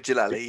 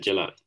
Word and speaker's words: Djalili: Omid Jalali, yeah. Yeah, that's it, Jalali, the comedian.